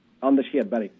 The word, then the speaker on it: Anders Hedberg: Oh, if we want to go way back, can I bring up, and you give me the proper pronunciation here, but Anders Hedberg. – Anders Hedberg, yes.